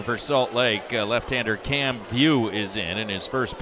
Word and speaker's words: Vieaux: And View is spelled the Cajun way, V-I-E-A-U-X.